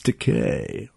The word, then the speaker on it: Takei: like I think George Takei is the only fucking impression I can do. I think uh, it's decay. Rhymes with gay.